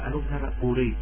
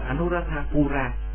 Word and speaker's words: Anuradhapura: Thì gọi là ở cái xứ gọi là Anuradhapuri, ở trong cái kinh văn là chúng tôi đọc là Anuradhapuri, còn ở bên xứ Thái Lan gọi là Anuradhapura, cũng là đại tịnh xá.